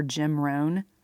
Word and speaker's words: Jim Rohn: I love the quote from motivational speaker Jim Rohn,